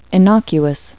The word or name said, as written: innocuous